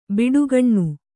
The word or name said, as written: ಬುಡಿಗಾನೆ